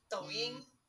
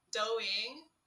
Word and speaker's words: Douyin: It's called Douyin. I love it. – Wait, what is this? I've never heard of it. – It's called... Let me Americanize this pronunciation for you guys. It's called Douyin.